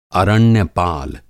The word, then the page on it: अरण्यपाल: Hindi